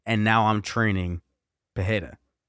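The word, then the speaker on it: Pereira: You nailed the Pajeda pronunciation a couple times.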